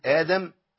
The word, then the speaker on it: آدم: لأن أصل كلمة آدم أقدم، فأبدلت الهمزة الثانية وأبدلت بحرف مد من جنس حركة الهمزة الأولى. الهمزة الأولى عليها فتحة بقت إيه؟